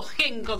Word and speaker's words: Henko: arroba espacio punto genko, que se escribe H-E-N-K-O punto ok.